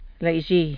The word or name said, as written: laizhi